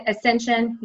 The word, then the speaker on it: Ascension: I mean, the most recent company or healthcare company that you work for, and it, I'm going to pronounce it, Accession, is that how I pronounce it? – Ascension, yes.